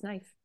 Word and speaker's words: knife: And I mean, it goes back to how to pronounce knife. – Right? Exactly. – So we don't pronounce it knife? – Knife. But do you know why the K is there?